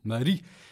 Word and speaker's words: Marí: Marí.